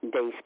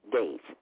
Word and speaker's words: Dais: Dez Kelly. D-A-I-S. Dez. – Lois Dez Kelly. – No, Dez. Dez. Dez. As in the days of the week.